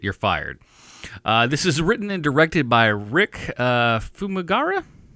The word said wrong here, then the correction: Famuyiwa: No, Famuyiwa.